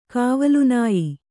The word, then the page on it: ಕಾವಲುನಾಯಿ: kāvalunāyi